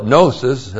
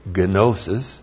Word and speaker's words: gnosis: From that verb, we have the noun, no, you call it gnosis, and no Greek would ever say gnosis. He would say gnosis. There are no silent letters in the Greek.